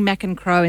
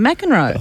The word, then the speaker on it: McEncroe: Because you pronounce my name correctly. You call me McEncroe instead of McEnroe.